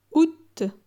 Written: Aout

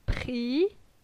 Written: Pri

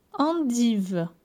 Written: Endivee

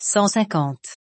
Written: 150

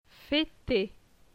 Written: Feter

